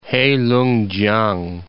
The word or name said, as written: Heilungkiang